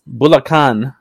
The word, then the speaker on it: Bulacan: Bulacan Bulacan International Airport, also known as New Manila Airport, six times the capacity of um, Ninoy Ninoy Aquino Airport.